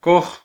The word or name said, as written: koj